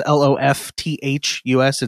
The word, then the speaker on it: Lofthus: So, my last name is spelled weird. It's Lofthus. It's a Norwegian name. The H is silent.